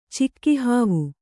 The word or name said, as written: ಚಿಕ್ಕಿಹಾವು